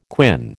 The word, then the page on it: quin: American English